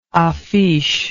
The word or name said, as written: affiche